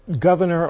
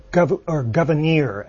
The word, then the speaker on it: Gouverneur: Well, I actually probably should have pronounced it Governor or Governeer, as Abigail Adams said it. It's spelled Gouverneur, but it may have been pronounced Governor.